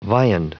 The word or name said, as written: viand